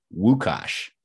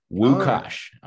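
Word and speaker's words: Łukasz: And so the guy that showed up, his name was Lucas, but in Poland, you would pronounce that Wukasz, like with a W. Wukasz.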